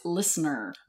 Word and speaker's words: LISNR: And so I started doing some research, and I found this company called LISNR. And it's... – Which is Listener, right? – Listener. – Listener. – Listener. I didn't want to pronounce it wrong.